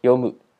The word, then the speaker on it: 読む: 接着我们再来看第四个动词，第四个动词它念作読む，